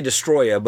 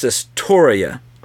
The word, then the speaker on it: Destoroyah: I'll pronounce it different every time I see that one too, because you want to say Destoroyah, but it's not. It's Destoroyah. – Destoroyah. – Yeah. So, but every time I see it, I just want to say Destoroyah because it just sounds cooler.